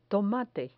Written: tomate

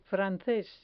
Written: frances

